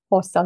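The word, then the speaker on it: Xhosa: Kosa w zasadzie tak się go wymawia polecam słuchaczom, żeby sobie wpisać Kosa Language w YouTubie i posłuchać, jak on brzmi.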